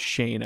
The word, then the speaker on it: Schoen: That it's pronounced Shane, I guess. – It is Shane, yeah.